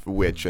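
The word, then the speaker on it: VVitch: But you know, it's double V, but it's pronounced Witch, I think.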